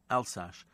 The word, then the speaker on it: Alsace: Is it Alsace it's pronounced? Yes. Alsace.